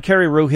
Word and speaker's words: Carreyrou: We're talking to John Carreyrou, his book. By the way, am I saying your name correctly, John? I've been looking at it for a week. – You are.